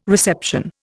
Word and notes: reception: Indian

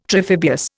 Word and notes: triphibious: Indian

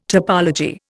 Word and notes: topology: Indian

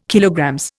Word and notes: KG: Indian

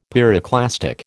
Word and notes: pyroclastic: American English

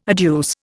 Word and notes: adduce: Indian

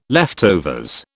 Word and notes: leftovers: British English

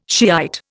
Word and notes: Shiite: Indian

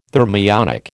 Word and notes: thermionic: American English

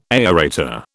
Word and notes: aerator: British English

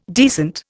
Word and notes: decent: Indian

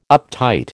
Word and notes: uptight: American English

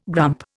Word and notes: grump: Indian

